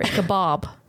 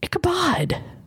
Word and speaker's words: Ichabod: Wait a minute, is it Ichabod or Ichabob? – Ichabod. – Really? – Yeah. – All my life I've said that wrong. Right. – Hey, Ichabob.